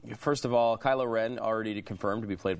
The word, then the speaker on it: Kylo Ren: That's Kylo Ren? Is that the way you pronounce it? – Yes.